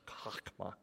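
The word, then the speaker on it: chokmah (חכמה): So there's a Hebrew word, it's the transliteration is C-H-O-C-M-A, chokmah. It's kind of a guttural sound.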